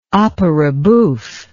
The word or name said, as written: opera bouffe